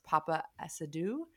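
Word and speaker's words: Paapa Essiedu: And then her husband, I think his name is pronounced Papa Asadu.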